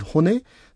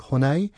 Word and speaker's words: hone: So the vowel sound e is pronounced as in the English word egg or Ted, so never A. So that's where I hear people saying te, like hone, which probably comes from other European languages, but e is always e as an egg or Ted.